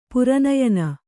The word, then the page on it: ಪುರನಯನ: pura nayana